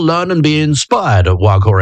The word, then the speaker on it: Waghor: That's spelled W-A-G-H-O-R, the Wag Ho Aquarium.